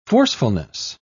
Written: forcefulness

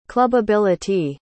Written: clubbability

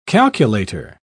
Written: calculator

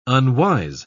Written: unwise

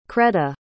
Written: creda

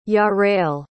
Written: jarrell

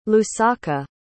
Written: lusaka

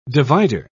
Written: divider

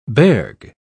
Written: berg

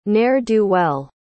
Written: ne'er-do-well

